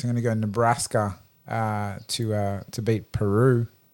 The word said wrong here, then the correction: Purdue: Purdue. Sorry, I always say it wrong. Purdue.